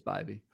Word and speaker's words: Bibee: And it's Bibee. Did I butcher that? I believe it's Bibee. – I believe it's Bibee.